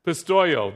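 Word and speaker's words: Pistoio (πιστεύω): where's my pastor? He pronounces all the Greek words for me. Oh, yeah, you can pronounce some of these words for me, Pastor. Okay. Pistoio, there it is.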